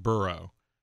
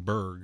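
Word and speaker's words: burgh: So Pittsburgh being the real name, Burrow. Right, B-O-U-R-G-H. – Scottish, right. And like Edinburgh. Supposed to be pronounced that way. However, it slowly became Berg, you know, just from people living here.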